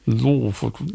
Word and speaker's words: Lofoten: Lofoten. – Lofoten.